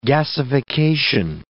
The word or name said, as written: gasification